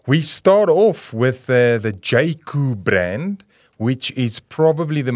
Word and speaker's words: Jaecoo: Of course, Jaco, spelled J-A-E-C-O-O, pronounced Jaco.